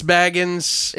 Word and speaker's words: Bagans: who I've been saying his name improperly, probably, technically. – Whatever. Whatever. It's spelled Baggins, so... – Baggins.